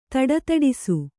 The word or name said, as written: ಧಡಧಡಿಸು